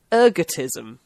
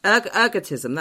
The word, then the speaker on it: ergotism: Ergotism. – Ergotism, that's it, yeah.